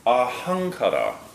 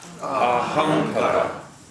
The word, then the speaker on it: ahankara: When an M and a K are together like that, you don't pronounce mka. You just stick your tongue up in your roof of your mouth and you just go So ahankara.